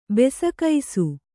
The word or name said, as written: ಬೆಸಕೈಸು